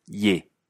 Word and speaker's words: il est: Él es ye. Que es como il est, donde il pierde la L y esa se pronuncia e. Y e se pronuncia e.